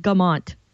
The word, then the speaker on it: Guimont: You can, but mostly it's just Guimont. So nice, vulgar, Americanized pronunciation.